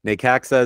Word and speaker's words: Necaxa: Necaxa is how you say their name. N-E-C-A-X-A. Necaxa.